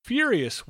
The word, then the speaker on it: furious: Now, be careful because you can almost imagine there's a small Y after the F. Furious. It's not furious, it's furious.